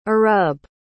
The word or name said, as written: arub